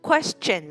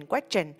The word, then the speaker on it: question: Question. Question là câu hỏi. Có rất là nhiều bạn mình đọc sai từ này. Mà các bạn đọc là question, question. Mình đọc là question.